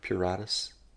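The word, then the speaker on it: pruritus: Puretis. – Did I say it wrong? – No, you said it right.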